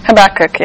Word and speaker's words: Habakkuk: Even thinking about, is it pronounced Habakkuk? – Habakkuk, yeah.